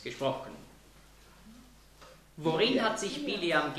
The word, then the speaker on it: Bileam: Balaam, das ist einfach die griechische Aussprache von Bileam. Das ist das Gleiche, ja? Im Neuen Testament werden oft Namen aus dem Alten Testament anders ausgesprochen, weil sie eben griechisch ausgesprochen werden.